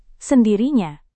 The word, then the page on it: sendirinya: indonesian